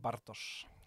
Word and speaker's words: Bartosz: Eh, ingen kunde uttala Bartosz ordentligt så att eh, eh, jag uttalar inte det rätt det heller. Det uttalas på ett helt annat sätt egentligen på polska. Det är, ju rullande där, på polska mm. Bartosz.